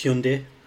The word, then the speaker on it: Hyundai: Did you know that that's not how you pronounce it? – I've heard people say Hyundai. – It's Hyundai. Hyundai.